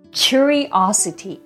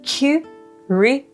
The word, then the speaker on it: curiosity: Curiosity 意味は好奇心音節は 5 つで Curiosity us, it になります。アクセントは O につくので curiosity になります。一緒に発音してみましょう。